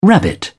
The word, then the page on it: rabbit: British English